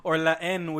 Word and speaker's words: La Haine: Let's keep going with the Frenchiness with La Haine or La N, which I learned is actually how you pronounce it.